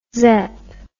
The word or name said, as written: zep